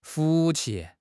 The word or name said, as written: 福气